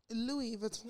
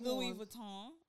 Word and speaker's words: Louis Vuitton: Louis Vuitton. – Louis Vuitton.